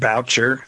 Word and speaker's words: Boucher: By the way, it's actually Richard Boucher, I think he pronounces it.